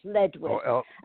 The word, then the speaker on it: Ledwith: So Saving Jesus by Michael, L-E-W-I-T-H, right? – L-E-D-W-I-T-H, Ledwith. And Michael... Yeah, L-E-D-W-I-T-H, Ledwith. And okay. – Michael is spelt M-I-C-E-A-L.